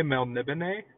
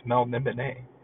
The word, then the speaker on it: Melniboné: I always say Melniboné, because it's, well, one, it's fun to say, like, it's an interesting way to say it, and also it sounds kind of more fancy. Plus, Melniboné, they have an accent on the E there, so I think you gotta say it like that, man. I think it's Melniboné. Melniboné.